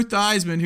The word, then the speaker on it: Theismann: And Joe Theismann, who just craves attention so much, he changed his name to rhyme with Heisman.